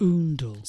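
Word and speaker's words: Oundle: Oondle.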